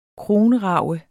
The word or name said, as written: kronrage